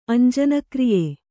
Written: ಅಂಜನಕ್ರಿಯೆ

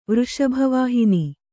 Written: ವೃಷಭವಾಹಿನಿ

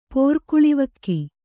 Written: ಪೊರ್ಕುಳಿವಕ್ಕಿ

